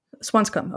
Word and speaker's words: Swanscombe: The Ash Smith family apparently resided in Swanscombe. Is that how you pronounce it? – Swanscombe. – Okay. – Yeah, so pretty much the way you did it.